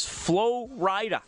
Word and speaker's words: Flo Rida: That, as I found out this afternoon, is Flo Rida. It's spelt like Florida, except it's two words, and there's a capital R.